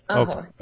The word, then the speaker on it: Aho: Is it Aho? Am I pronouncing that correctly? – Yes, Aho.